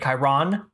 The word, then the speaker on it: Chiron: the teacher that's reading the books to me pronounces it Chiron. She puts the emphasis on the Ron, which I love.